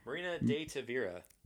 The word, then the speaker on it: Marina de Tavira: Marina Detiv. Tavira. – Marina De Tavira.